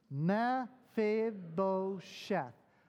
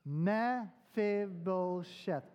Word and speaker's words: Mephibosheth: Mephibosheth. Mephibosheth.